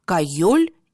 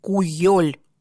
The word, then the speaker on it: Кёль: «Итык» – это священный, почитаемый, «Кёль» или «Куёль» – вот как-то так по-другому произносится это слово – это озеро, то есть буквально «священное озеро».